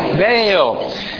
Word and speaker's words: Baal: You know Baal because it gets translated literally in your Bibles as this, which is, you pronounce it as Baal.